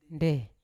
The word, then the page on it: nde: Yawa